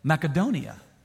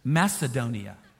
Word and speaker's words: Macedonia: During the night, Paul had a vision of a man in Macedonia. Some would pronounce it Macedonia, but those of us who know the proper Greek phonetics would say Macedonia.